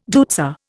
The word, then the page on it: deutzia: Indian